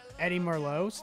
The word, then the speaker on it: Eddie Merlot's: It's Eddie Noir. It's 4-star restaurant. – This nigga can't even pronounce it, bro. That's it, bro. – Eddie Merlot's.